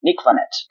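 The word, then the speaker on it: Nick Vannett: E só um comentário em cima do Germano, a pronúncia tá certíssima, Germano, é realmente Nick Vanette.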